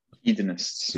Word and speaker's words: hedonists: How do you say it? Hedoics? – Hedonists. – Hedonists.